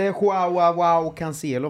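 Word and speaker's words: João Cancelo: Har inte Joao Cancelo varit väldigt bra, eller? – Jo, han är med.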